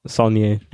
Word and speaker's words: Saulnier: I butcher that name. Like, it's Jeremy Saulnier.